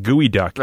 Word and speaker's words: geoduck: G-E-O duck. Like Geo duck, but it's called Gooey Duck. – That's made up. That's not real. – It's not made up. – It's spelled Geoduck, but pronounced Gooey Duck.